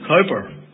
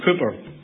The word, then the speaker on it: Cowper: The first hymn that, well, we are very literal in Northern Ireland in our pronunciation of words, and we call him William Cowper. Actually, his name is William Cooper. I think that's how it should be pronounced.